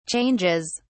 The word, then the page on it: changes: British English